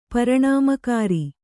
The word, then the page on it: ಪರಿಣಾಮಕಾರಿ: pariṇāmakāri; Kannada